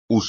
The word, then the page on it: uso: espagnol